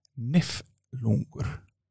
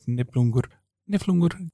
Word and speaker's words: Niflungur: The Icelandic name is spelled with an F, while the German has a B instead. Though in Icelandic we often pronounce that F as a B. Niflungur instead of Niflungur.